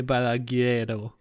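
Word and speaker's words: Balagueró: Sleep Tight von Jaume Belaguero. Hundertprozentig falsch ausgesprochen. Jaume Belaguero. Richtig, genau. genau so geht's.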